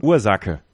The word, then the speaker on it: Ursache: Aber wie wird er ausgesprochen? – Ursache. Weil das CH wird mit als K.